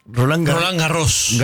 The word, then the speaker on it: Roland Garros: Yo lo dije mal. – Sí. – Bueno, sí. – Roland Garros. – Garros, claro. La S final. – La S final a veces va, a veces no va.